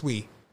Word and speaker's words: Uy: So when I realized that there were Wees, so the real technical way to pronounce it is like we, but with a glottal stop. Like a we. – Oui. – So it's like French yes in yeah. French. Oui. Like oui, oui, like yeah.